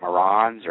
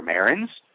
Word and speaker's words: Marans: In fact, one lady had mentioned, I guess you saw it on a blog or forum, where Marans, you may pronounce it Marans or Marins. I know I've heard it pronounced all three of those different ways.